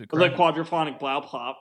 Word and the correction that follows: Blaupunkt: It is all one word. It's spelled B-L-A-U-P-U-N-K-T. It's like... – Blaupunkt? – It's German. It's Blaupunkt.